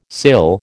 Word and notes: sill: American English

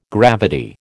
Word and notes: gravity: American English

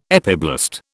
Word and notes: epiblast: British English

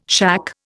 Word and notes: shack: Indian

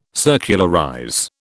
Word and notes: circularize: British English

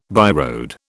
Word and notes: byroad: British English

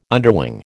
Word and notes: underwing: American English

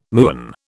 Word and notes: muon: British English

